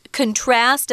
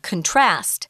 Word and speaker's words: contrast: uh, one thing on pronunciation here, guys, if you use contrast as a verb, then the the heavy syllable of course is on the second part of the word contrast but if it's a noun contrast uh, wow there's a great contrast between the colors that you're wearing one's light and one's dark so contrast noun contrast verb.